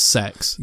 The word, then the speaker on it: CEX: I was a bit confused how to pronounce CEX because I recently saw their YouTube advert. – Oh, okay. – And they pronounce it as sex.